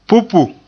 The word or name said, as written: pupu